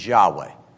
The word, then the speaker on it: Yahweh: He goes, in spite of the uncertainties that exist, there is now strong scholarly consensus that the original pronunciation of the Tetragrammaton is Yahweh. So YHWH with the correct vowels would say Yahweh.